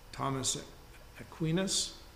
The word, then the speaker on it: Thomas Aquinas: Thomas Aquinas, that's how you say his name, "Jews who refuse to convert are regarded as deliberately defiant rather than invincibly ignorant."